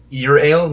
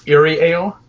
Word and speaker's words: Euryale: Because the beer I'm drinking is from a Riverside brewery called Your Ale. – Mm-hmm. – Which is spelled E-U-R-Y-A-L-E, but it's Your Ale, not Eerie Ale.